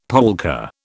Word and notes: polka: British English

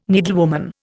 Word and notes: needlewoman: Indian

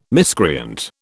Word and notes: miscreant: British English

